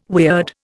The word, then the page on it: weird: Indian